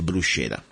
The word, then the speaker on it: bruschetta: I want to tell you exactly what how to say it in Italian. It's called bruschetta. And let me spell it for you. B-R-U-S-C-H-E-T-T-A, okay?